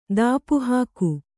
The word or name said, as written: ಧೂಪಹಾಕು